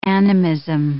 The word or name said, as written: animism